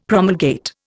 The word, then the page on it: promulgate: Indian